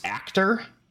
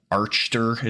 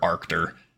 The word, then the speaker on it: Archtor: But at the first record that something was abnormal was on the 15th of December of 1900, when the steamer, I believe it's pronounced Actor, if I remember correctly, spelt A-R-C-H-T-O-R, Archter is kind of what it looks like, but I'm pretty sure it's pronounced Actor, Arctor, something like that.